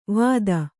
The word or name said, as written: ವಾದ